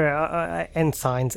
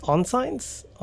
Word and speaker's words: ensign: Yeah, so Lieutenant and two ensigns. Ensigns? Onsigns? Onsign? – Ensign. – Ensign. – Is that how you say it? – Ensign and Lieutenant.